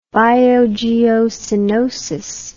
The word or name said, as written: biogeocenosis